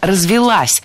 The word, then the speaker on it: развилась: Но такого вида транспорт – это все-таки когда уже гужевая тяга прям развилась, появились все-все-все аксессуары, и люди перестали ходить пешком недавно. – Развилась неверно, правильно, развелась. Ударение ставим на окончание.